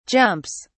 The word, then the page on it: jumps: British English